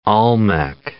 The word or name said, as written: Olmec